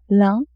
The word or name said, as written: lin